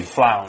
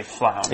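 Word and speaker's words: flaounes: But we don't say the S, we just say flown. – Flown, that's right. – Even though there's an S, you just say flown.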